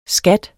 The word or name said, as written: skatter